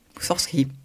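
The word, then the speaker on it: Moussorgski: Tableau d'une exposition de Moussorski. Moussorski, tout à fait par l'Orchestre national du Capitole et sous la direction de Tugan Sokiev.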